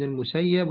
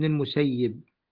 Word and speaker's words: المسيب: وقال زيد بن ثابت رضي الله عنه بالنسبة لبقى هتلاقي الإخوة والأخوات عندهم في النسخ لسعيد بن المسيب وسعيد بن المسيب كلاهما صحيح من ناحية النطق. سعيد بن المسيب وسعيد بن المسيب كلاهما صحيح عشان لا يلتبس الأمر يعني على الضبط عند بعض النسخ. فالمكتوب عند المسيب أو المسيب فكلاهما صحيح.